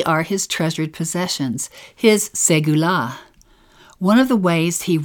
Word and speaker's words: segula: Segula. This special Hebrew word is one of my favorites, pronounced segula. Say it out loud right now.